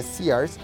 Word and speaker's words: Sears: hay una tienda departamental que ustedes le dirán Sears, yo le digo Sears, no, no se dice Sears, se dice Sears,